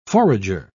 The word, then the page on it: forager: British English